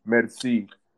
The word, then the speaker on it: merci: and the uh, correct pronunciation is uh, merci.